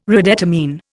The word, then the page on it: redetermine: Indian